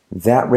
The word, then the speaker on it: that: That reminds me. That reminds me. Fíjate la, que la T final de that casi no se dice.